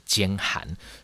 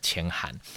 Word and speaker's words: 箋函: 所以这个一定要先跟各位澄清，它是兼函，不是签函。而而且更重要是，事实上在档案里面也的的确确是写兼函，是我当时一时不察，念错，念成了签函。